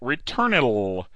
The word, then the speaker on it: Returnil: Returnal. R-E-T-U-R-N-I-L.